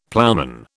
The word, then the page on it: ploughman: British English